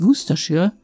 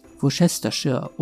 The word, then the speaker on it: Worcestershire: Allerdings ist es hier, wie mit vielen anderen englischen Vokabeln, die Aussprache ist völlig anders als die Schreibweise. Es heißt Worcestershire und nicht Worcestershire oder so ähnlich.